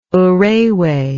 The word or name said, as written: urewe